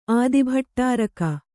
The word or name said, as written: ಆದಿಭಟ್ಟಾರಕ